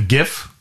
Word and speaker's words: GIF: I sent you a GIF or a GIF. What do you call it? – A GIF. – A GIF? Hard G.